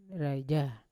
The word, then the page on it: raija: Yawa